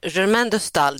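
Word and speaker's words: Germaine de Staël: Ja, alltså Germaine de Stal, det är inte lätt att uttala, men så, så ska det uttalas.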